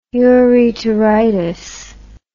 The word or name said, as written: ureteritis